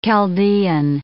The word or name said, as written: Chaldean